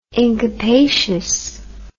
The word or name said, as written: incapacious